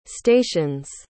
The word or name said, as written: stations